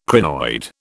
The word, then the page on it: crinoid: British English